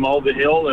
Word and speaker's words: Mulvihill: Well, we went to, I can spell it for you, M-U-L-V-I-H-I-L-L.